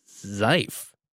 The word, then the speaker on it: GIF: Also, plus one for the hard G in GIF. Oh my goodness. Are you serious? they were a gift to the internet. Oh. But if you want to equally infuriate the hard G and soft G crowds, you can go with Mike Rugnetta's proposed pronunciation.